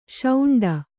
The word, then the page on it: ಶುಂಡ: śuṇḍa